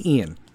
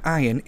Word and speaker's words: Ian: Okay, so Hurricane Ian. It's not Ian. It's Ian. It's spelt with an I, but it's pronounced with an E.